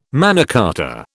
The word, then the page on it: Magna Carta: British English